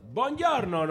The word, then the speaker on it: Buongiorno: Buongiorno, ¿no? – Buongiorno.